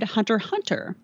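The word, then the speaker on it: Hunter × Hunter: And then I most recently did Hunter Hunter. And all I knew about that show going in is that you don't pronounce the X. – I was scolded in public for pronouncing the X about a month ago, so I know your pain.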